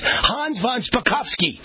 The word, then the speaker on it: Hans von Spakovsky: Hans von Spakovsky. Did I get it right? – You got it exactly right.